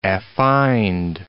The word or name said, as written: affined